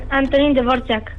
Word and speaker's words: Antonín Dvořák: Antonín Dvořák.